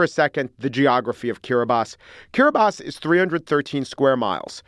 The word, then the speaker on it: Kiribati: Excellent, excellent flags, as is the flag of Kiribati, which I found out is actually pronounced Kiribati.